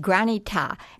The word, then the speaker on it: granita: Well, it's pronounced granita, and granita.